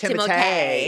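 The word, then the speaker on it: Timothée: Did you know his name is actually pronounced Timotei? Did you know that? – Yes.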